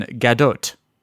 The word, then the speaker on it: Gadot: And you do pronounce the T in Gadot.